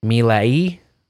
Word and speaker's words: Milei: Can you spell it? – M I L E I. – Hmm. – Milai. If you're trying to pronounce it in Espanol, I don't fucking know, man.